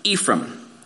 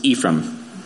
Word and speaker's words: Ephraim: How many hear it pronounced Ephraim? You might hear it pronounced, actually, the Hebrew Ephraim. Ephraim. That makes you sound important. We're not told that these boys are twins. It's possible. But here we learned the birth of Ephraim, or Ephraim, how you say it.